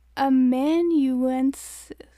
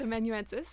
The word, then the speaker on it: amanuensis: Anyway, so reading it, sounding it out, it's amanuensis. – Yes, I looked up the pronunciation. Cool. And you are right, it's amanuensis.